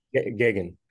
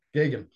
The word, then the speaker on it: Geoghegan: After, by chance, meeting director, and I hope I don't mispronounce his last name, Ted Geoghegan. Did I get that right? – Geoghegan. – Geoghegan. – Yep.